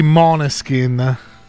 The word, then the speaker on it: Måneskin: Ci sono stati anche i Måneskin, hai la pronuncia corretta. – Mi hanno detto che si dice così, mi hanno sgridato altre volte perché insomma Måneskin, tutti dicono male, ti viene da dire male.